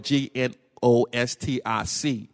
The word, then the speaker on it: Gnostic: And when we say Gnosticism, uh, it's, it's spelled G N O S T I C, Gnostic.